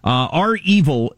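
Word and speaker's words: REvil: You may be aware of the ransomware group Reval. Is that the way you pronounce it? It's capital R, capital E, V-I-L. How do you pronounce it? – Or is it R-Evil? – R-Evil, is that the way they say it? Okay.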